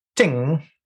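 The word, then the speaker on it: ting (听): By the way, Jim, that ting means to listen in Mandarin. And ting not pronounced correctly also means to stop, doesn't it? – Yes, it does. Yeah, well, it's in different tones. There are four tones.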